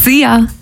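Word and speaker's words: szia: See ya. Hallo und Tschüss, könnt ihr euch ganz easy merken. Wird einfach ausgesprochen wie unser Energy Star.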